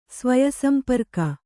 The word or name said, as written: ಸ್ವಯಸಂಪರ್ಕ